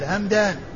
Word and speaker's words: همدان: فهو بالميم والدال، الميم الساكنة والدال همدان.